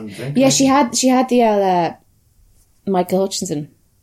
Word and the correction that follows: Hutchence: Michael Hutchence.